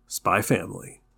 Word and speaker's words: Spy x Family: But yeah, so that anime is Spy X Family, which is of course pronounced Spy Family. The X is silent,